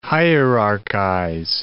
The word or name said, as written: hierarchize